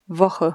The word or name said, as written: Woche